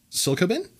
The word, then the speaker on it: psilocybin: House Bill 1802, which calls for a clinical study on using, I'm going to mispronounce this, psilocybin.